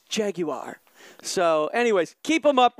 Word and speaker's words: Jaguar: And he says, great fun show, even if can't pronounce Jaguar. Now, I just wanted to keep it down and dirty, man. It's California Jaguar. – Jaguar.